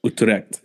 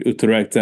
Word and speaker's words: Utrecht: Utrecht. – Yeah, I need to pronounce that probably. Utrecht.